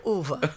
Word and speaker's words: Ove: It's like saying Hoover. a man like Uva.